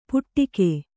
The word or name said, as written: ಪುಟ್ಟಿಕೆ